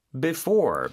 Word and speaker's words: before: 자, 뭐뭐 하기 전에 라는 단어가 바로 Before, B-E-F-O-R-E. – 맞아요. 그리고 강세가 이음절에 있다고 했었죠. 그래서 before 이렇게 발음이 됩니다.